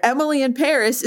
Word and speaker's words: Emily in Paris: Emily in Paris is supposed to be pronounced with a French accent, so Emily and Paris rhyme.